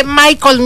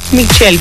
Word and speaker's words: Michelle: Ay, y se dice Michael, mija. Michael. – Michelle, corazón. A ver cómo se dice. Porque es aquí en México. – Es Michelle, ¿no? Michael se escribe diferente.